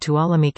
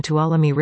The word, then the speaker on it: Tuolumne: Tuolumne River and streams. The county, whose name rhymes with "Follow Me,"